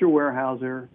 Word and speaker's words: Weyerhaeuser: Let me spell that again, because it's not how you would think it's spelled. It's Weyerhaeuser. Mr. Weyerhaeuser was German.